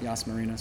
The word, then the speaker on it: Yas Marina: Yas Marina.